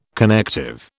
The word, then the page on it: connective: British English